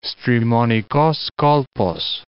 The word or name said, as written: Strimonikos Kolpos